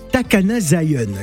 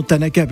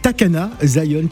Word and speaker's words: Takana Zion: Alors pour ne pas fâcher tous les Guinéens qui nous écoutent ce matin, c'est bien Takana Zion, hein, qui est une star et non Tanaka, parce que tout à l'heure euh, j'ai prononcé ça en japonais, c'est pas du tout, euh, euh, c'est Takana Zion qui est donc une star du côté de la Guinée-Conakry.